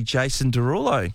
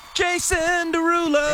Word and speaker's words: Jason Derulo: We have a double pass, by the way, to see Jason Derulo. – Say it properly. – Jason Derulo.